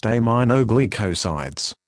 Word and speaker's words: aminoglycosides: Amikacin is an antibiotic that belongs to a group of medicines called aminoglycosides, pronounced aminoglycosides. It is used to treat serious bacterial infections.